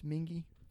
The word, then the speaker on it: Mingua: It's spelled M-I-N-G-U-A. It looks like Mingua, but it's Mingy.